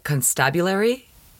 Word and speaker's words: constabulary: Now, the word that is written on the building, I'm not pronouncing this right, constabulary, like constable, but you have to change the emphasis on the syllables.